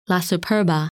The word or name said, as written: La Superba